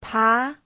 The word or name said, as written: pa2